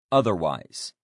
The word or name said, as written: otherwise